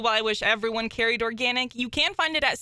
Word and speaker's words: Organyc: It's called Organic. It's actually spelled with a Y, so O-R-G-A-N-Y-C. And they have an entire line of feminine products.